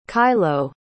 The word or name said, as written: kylo